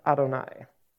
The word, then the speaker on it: Adonai: And it's pronounced Adonai. Yod, He, Vav, He is not pronounced, wouldn't be pronounced as Adonai. It's what is read versus what is seen.